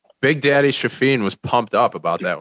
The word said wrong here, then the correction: Chafin: Did you just call him Shafin? – Yeah, how do you pronounce it? Chafin? – Are you kidding me? – Dude's Chafin? – You called him Shafin? – What do you call him? – His name is Andrew Chafin.